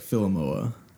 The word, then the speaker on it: Filimaua: So if you know how to pronounce like Samoa, it's... – I do. – It's like Filimaua.